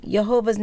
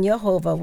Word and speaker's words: Jehovah: So since there's no J sound in the Hebrew language, it's not even possible that the Y in Jehovah was ever pronounced like a J.